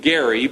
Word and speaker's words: Gerry: Now, he pronounced his name Gerry, but for whatever reason, when they named this practice for him, it ended up the G got softened somewhere along the line, and we call it gerrymandering.